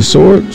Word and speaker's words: sword: If you falling on the sword with the silent W, then I'm falling on the sword with the silent W.